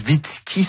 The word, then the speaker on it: Zwicky: Donc un, Fritz Zwicky, ça s'écrit Z-W-I-C-K-Y. Donc je sais pas si c'est Zwicky si on le prononce est-européen. Mais en tout cas, bon, c'était Fritz Zwicky, hein, c'est ça Julie? – Oui, exactement.